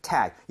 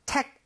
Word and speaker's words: tag: So, tag. 얘는 tech 아닙니다. G는 CK라는 스펠링보다 부드러워요. – 그쵸. – 그렇기 때문에 tech 하지 마시고요. tag, Tag. – tag. 이런 느낌으로 부드럽게 한번 해보세요.